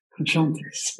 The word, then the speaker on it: Jontes: There's an inside joke there that we mispronounce her name the Spanish way because we both speak Spanish, and so we sort of assumed it was that. And it was several years before she finally one day said, you know, it's pronounced Jontes.